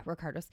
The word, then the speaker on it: Ricardos: Yeah, Ricardos.